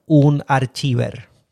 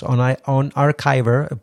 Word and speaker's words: Unarchiver: En español, Unarchiver. Y por favor, no lo pronuncies así. Es OnArchiver.